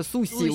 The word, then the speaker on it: суши: Хотя мы говорим суши, да, между тем это суси у них, да.